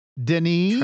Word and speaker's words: Denis: Denis. – Try again. – Go ahead. He's French, dude. You got to pronounce his name with like. – Well, then pronounce his name for me. – Denis.